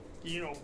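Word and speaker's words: Yeenoghu: Yinogu, who is the patron of the Knoll. – Yinogu. – Yinogu. – How do you spell that? – Y-E-E. – Y-E-N-O-G-H-U.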